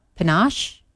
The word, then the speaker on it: Penuche: It's National Penuche Fudge Day, P-E-N-U-C-H-E. – Oh. – Panache? – Penuche?